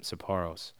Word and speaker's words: Sapporo: There was a bunch of Japanese guys sitting at the bar drinking cold Sapporos. They have Sapporo on draft. I hope I'm saying that right. Yep, Sapporos.